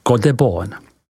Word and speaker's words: Côte de Beaune: Tato vyhlášená podoblast Burgundska se rozkládá od města Dijon po obec Echeveron, vyslovujeme Echeveron, která je první obcí následující vinařské podoblasti Côte de Beaune, vyslovujeme Côte de Beaune.